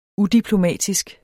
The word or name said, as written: udiplomatisk